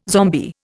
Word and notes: zombie: Indian

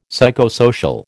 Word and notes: psychosocial: American English

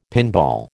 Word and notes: pinball: American English

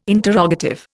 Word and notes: interrogative: Indian

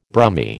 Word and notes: Brummie: American English

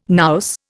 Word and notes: nous: Indian

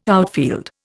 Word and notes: outfield: Indian

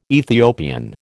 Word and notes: Ethiopian: American English

